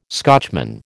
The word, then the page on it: Scotchman: American English